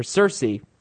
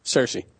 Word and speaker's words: Cersei: He says, hello, Tyrion, Jamie, and Xerxy. Or Cersei. – Cersei.